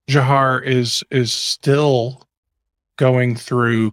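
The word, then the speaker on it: Dzhokhar: But, um, but it's, but it's actually Jahar is how he pronounced it.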